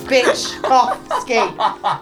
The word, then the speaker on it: Byczkowski: I've written down Byczkowski. – That's exactly right. Well done.